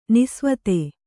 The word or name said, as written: ನಿಸೇವಿತ